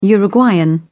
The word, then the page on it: uruguayan: British English